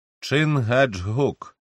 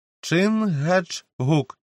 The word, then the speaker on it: Чингачгук: «Чингачгук», – повільно вимовив вождь своє ім'я, наголошуючи на кожному складі. «Великий змій», мовою інгізів. – «Чингачгук», – повторила Гетті так само роздільно.